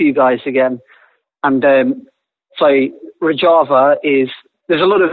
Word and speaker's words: Rojava: Rojava, where he not only fought ISIS but talked some economic ideas to the people of Rojava, or Rojava, as I say. I pronounce it the Spanish way sometimes.